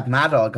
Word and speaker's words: Madog: So the Mattock Center, how do you pronounce that? Madog or Mattock? – You've got it. Yeah, Madog, the Madog Center for Welsh Studies.